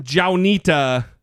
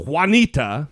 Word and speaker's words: Juanita: It's not Johnita Broderick. It's Juanita. It's spelled like Juanita.